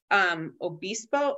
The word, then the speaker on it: Obispo: And then to the south is West Obispo, O-B-I-S-P-O. I think I'm pronouncing that correctly. You know me and my names. – Obispo. Yeah. – Obispo. Yep.